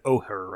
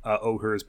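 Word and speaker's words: Ojer: how do you pronounce these? It's like Ohur, right? – Yeah.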